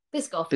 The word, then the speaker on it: Biscoff: Biscoff? – Biscoff, yeah.